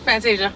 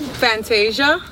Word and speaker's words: Fantashia: Fantasia. Yes. – Fantasia? – Yes. – How do you say your name? – Fantasia.